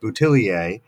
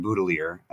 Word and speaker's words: Boutilier: And for those who are wondering about my pronunciation, because it looks like it ought to be pronounced Boutillier, Clive Baudelaire was from the Canadian province of Nova Scotia, which has a large population of people who are of French descent, but who have long since abandoned French language. And so when I corresponded with the family, they let me know that they pronounce the family name Baudelaire.